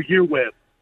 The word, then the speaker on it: EURweb: Your EURWEB. – It's Your Web. You can pronounce it two ways. EUR Web. – Uh-huh. – It's much easier. Or Your Web. Awkward to say.